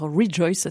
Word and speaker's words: Rejoyce: Rejoice, ça s'écrit R-E-J-O-Y-C-E.